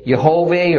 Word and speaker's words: Yehovah: Yehovah or Yahweh, depending on which Hebrew scholar you believe is correct as to this name's pronunciation, is the completely unique word that God says is his personal name.